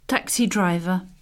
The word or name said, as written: taxi driver